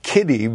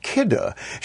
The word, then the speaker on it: Kidde: She is the fire safety educator with a company called Kidde that we might always pronounce as Kiddy because it's K-I-D-D-E. You say, oh, I've seen that name. I have those products, but it's pronounced KIDDA.